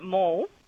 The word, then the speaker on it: Mall: Do you pronounce it Mal or Mall? – Look, I've always pronounced it Mall.